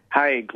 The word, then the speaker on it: Haig: Haig, like vague, but with an H.